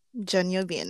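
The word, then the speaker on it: Jeon Yo Bin (전여빈): Okay, let me see if I can say this right. Jeon Yo Bin. Did I say that right? Jeon Yo Bin. Mm-hmm. okay.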